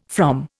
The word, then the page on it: from: Indian